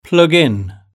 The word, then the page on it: plug in: British English